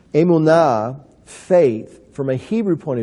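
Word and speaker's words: emunah (אמונה): It's pronounced emunah. And emunah, faith, is spelled aleph, mem, vav, nun, he. Emunah.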